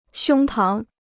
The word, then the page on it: 胸膛: xiōng táng